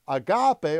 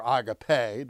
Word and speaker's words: agape: And finally, agape or agape, depending on how you want to pronounce that.